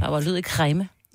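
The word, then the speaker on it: Kregme: Jeg bor kræme. – Ja. Kræme. – Kræme. Den var god.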